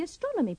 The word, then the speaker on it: astronomy: Remember to put the stress on the first O of astronomy and astronomer. – This is Patrick Dower. He does the astronomy program on BBC Four.